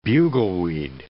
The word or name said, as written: bugleweed